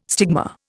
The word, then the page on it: stigma: Indian